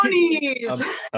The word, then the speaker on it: bronies: It rhymes with phonies. – My Little Ponies? – Yeah. – Where does the bro come into that? – Cassandra, bronies!